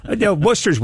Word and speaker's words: Worcester: Worcester Seer? – No, everybody gets... Worcester's well-known around the country. Everybody, most people know Worcester, even though it's spelled Worcester or whatever. But yeah, everybody knows Worcester.